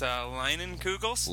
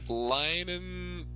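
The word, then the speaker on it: Leinenkugel's: It's Leinenkugels. It's German. Leinenkugels. – Leinenkugels.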